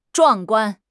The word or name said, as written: 壮观